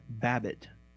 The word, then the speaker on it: Bobet: where Edward Bobbitt, spelled B-O-B-E-T, but pronounced Babbitt, was killed by natives.